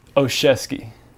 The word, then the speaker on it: Oshesky: Oshesky. – Osheski. – Yes, everything's silent, but the O and the ski, pretty much.